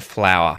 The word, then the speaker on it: flour: As you have probably just worked out, they are exactly the same pronunciation. Flour, I make bread with flour. I went into the garden and picked a flower. They're pronounced exactly the same way.